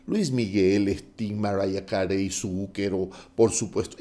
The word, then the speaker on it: Zucchero: Se escribe Z-U-C-C-H-E-R-O, e sí, Zucchero, Zucchero en realidad.